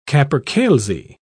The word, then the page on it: capercailzie: British English